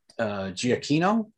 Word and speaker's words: Giacchino: That I hope I get his name right, Michael Giacchino. Is yep, that how to say his name? – That's correct.